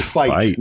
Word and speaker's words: Feit: It's Feit, not Feet.